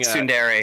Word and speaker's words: Tsundere: Tsundere.